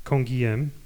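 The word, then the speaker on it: Canguilhem: E, Kongiem, bo tak ja go wymawiam, był z Prowansji.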